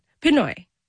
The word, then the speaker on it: Pinoy: My associate producer, who's also Filipino, says it's yes. Pinoy. You're calling it Pino. – Pinoy. Yes, Filipino. – Say it again. – Jack's Filipinos. And then Pinoy is like our nomenclature.